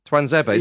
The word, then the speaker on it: Tuanzebe: Do you mean Axel Tuanzebe? – Twanzebe. Is that how you pronounce it? – I think so, yeah. – Twanzebe. – We know what you meant. – Twanzebe, not yeah. Twanzebe. – Yeah. – Okay. – Yeah. – We'll go for that then.